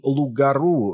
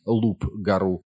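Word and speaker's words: loup-garou: Проте, як зауважив Біл, Лугару, він вимовляв це слово як Луп-гару. Слово «лу» французькою мовою пишеться із літерою «п» на кінці, а згідно правил читання, більшість приголосних у кінці французьких слів не вимовляються.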